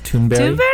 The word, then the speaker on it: Thunberg: Greta Thunberg Imperiosa. Yeah, I heard, actually, it's pronounced Thunberg. Even though it's spelled Thunberg, which is cuter.